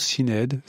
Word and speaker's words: Sinéad: Je ne sais jamais comment on prononce Sinéad.